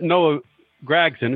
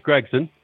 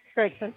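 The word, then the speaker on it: Gragson: So I'm not supposed to pronounce it Noah Gregson. It's Gregson. – Gregson.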